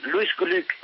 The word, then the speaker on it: Glück: Pues Louise Glück, y permítame que lo pronuncie en su pronunciación alemana, porque Glück es además, no sé si ustedes lo saben, significa en alemán suerte.